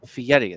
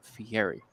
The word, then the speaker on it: Fieri: Apparently it's Fieri. It's not Fieri.